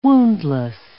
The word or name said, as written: woundless